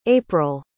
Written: apryl

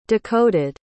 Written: decoded

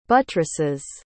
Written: buttresses

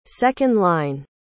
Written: second-line

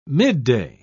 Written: midday

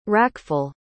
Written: rackful

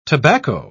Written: tobacco

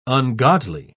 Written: ungodly

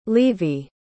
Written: levie

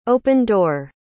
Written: open-door

